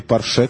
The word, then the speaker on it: Порше: За рулем Порш. Или Порше, как правильно говорит по-русски, врезалась в самосвал 8 июня, сообщает Reuters.